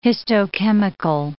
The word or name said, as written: histochemical